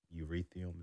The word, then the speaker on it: Ethereum: And that's why you've seen like Bitcoin and Ethereum, is that how you say it? Mm-hmm.